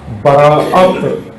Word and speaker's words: براءت: اصل کیا کہنا چاہیے تھا, براءت, براءت الف جو بیچ میں اس پر حمزہ ڈال کے براءت, لیکن وہ عامیانہ تلفظ میں برات بن گیا.